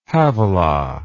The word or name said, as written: havilah